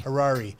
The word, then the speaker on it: Harari: Noah Hirashi or Harari? – Harari.